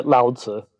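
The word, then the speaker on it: Lao Tzu: You talk mainly about three main teachers, Confucius, Lao Tse, did I say that correctly? – Yeah, Lao Tse. – Yep. Lao Tse, uh-huh.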